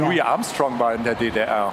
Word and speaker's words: Armstrong: Louis Armstrong.